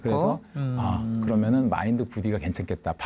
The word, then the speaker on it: Buddhi: 근데 그 BUDDI 스펠링은 버디라는 영어 친구란 뜻이 있는데 버디 BUDDY를 그대로 쓰면 네. 너무 이게 알고 있는 단어를 결합한 그렇죠. 게 이상해서 약간 좀 I로 썼는데 그럼 발음이 부디가 아니라 여전히 버디 같아요. 네.